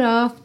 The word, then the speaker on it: of: Percebe o rarará no T do out, que fica aura, ararara, out of, já vai pro of, que se escreve OF, mas sempre soa OV, ok?